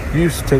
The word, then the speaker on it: used to: Again, use, use, then to, but we pronounce with a schwa sound, used to.